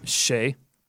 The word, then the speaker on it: Shai: How do you say his first name? Shay. Yep.